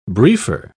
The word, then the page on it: briefer: British English